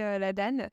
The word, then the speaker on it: ADAN: déjà, est-ce que tu peux te présenter et me dire ce que tu as fait avant l'ADAN ou l'ADAN Je ne sais jamais comment on prononce. – Alors, on accepte les deux. Nous, on va plutôt prononcer l'ADAN.